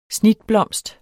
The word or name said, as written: snitblomst